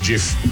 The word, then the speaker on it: GIF: And yes, it's pronounced GIF. I will die on that hill. And so will the guy who invented them, who insists that it is pronounced GIF.